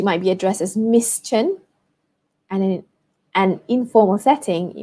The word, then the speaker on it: Tan: So, for example, if her family name is uh, Tan, spelled T-A-N, pronounced Chen, for example, Chen Li Mei, Chen Li Mei, Chen would be at the front, and then Li Mei.